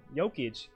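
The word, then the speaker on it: Jokić: People are even hinting towards if Denver does good enough, Jokic, I almost said it wrong, Jokic can be a three-time MVP in a row winner.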